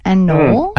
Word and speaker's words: anole: Because I had to use an anole to properly pronounce it. – An anole? – Anole, yeah.